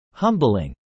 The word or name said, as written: humbling